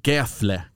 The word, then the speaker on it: Gefle: Eller Gefle kanske man ska säga här nere. Jag har fått lära mig att F ska uttalas F. Men där jag kommer ifrån så heter det Gävle i alla fall.